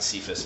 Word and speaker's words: Kepha: Or you look in the Bible, some people pronounce it Cephas, but I don't know. I like going with the way they pronounce it in Aramaic, which is Kepha.